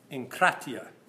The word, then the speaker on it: enkratia: What I want to do is let's review some common Greek dictionary definitions for enkratia. Okay? That's the way you would say it, unless you're George, but he's not here tonight to correct me. Okay? But it's how you pronounce this word.